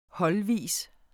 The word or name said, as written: holdvis